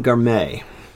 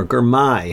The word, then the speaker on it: Girmay: On a positive side, Biniam Girmay, or Girmay, however you want to say it. I think Girmay is the actual proper pronunciation on that.